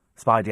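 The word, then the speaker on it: Speidi: Spidey as in rhyming with Heidi, I suppose.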